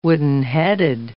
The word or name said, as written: woodenheaded